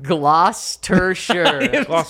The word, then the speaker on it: Gloucestershire: It's pronounced Gloucestershire. – Gloucestershire. – You were right there. – I was thinking, like, just based on the spelling, it's got to sound like Worcestershire.